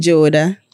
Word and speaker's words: Jowoda: Jowoda.